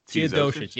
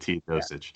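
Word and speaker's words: Teodosić: Milos Teodosic. – Teodosic, yes. – I think that's how that's pronounced. Yeah. – Yes. And yeah, I'm Russian, and I mess up these Eastern European names. – as the resident name pronouncer of Locked On Knicks, I will give that my stamp of approval at Teodosic.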